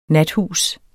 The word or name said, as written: nathus